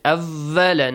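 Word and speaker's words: أَوَّلَ: Make sure waw is not pronounced as V in English. Awwala.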